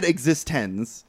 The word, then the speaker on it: eXistenZ: It's spelled, it's said eXistenZ, but it's spelled E-X. X is very big. It's like a capital X, I-S-T-E-N, and capital Z.